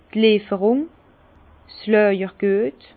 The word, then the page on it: d' Lieferung, 's Làjergüet: Alsatian; Bas-Rhin; Herrlisheim